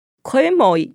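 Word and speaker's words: Quemoy: 八二三炮战让金门成为世界瞩目的焦点，全球各大主流媒体都大幅报道这场金门危机，也就 Quyen Moi Crisis。 Quyen Moi 就是金门的福建话发音，那它也因为这个名字而跃上世界的舞台。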